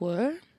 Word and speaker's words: Weir: Bob Weir. Where? – How do you spell that? – W-E-I-R from the Grateful Dead. – Weir. Bob Weir.